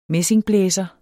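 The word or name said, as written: MESSINGBLÆSEREN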